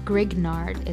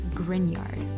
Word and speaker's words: Grignard: I just realized a few days ago that I've been pronouncing it wrong all this time. It's not Grignard, it's Grignard.